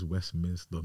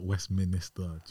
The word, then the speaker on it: Westminster: It's Westminster, not Westminster. Do you get it? – Westminster. – It's not Westminster.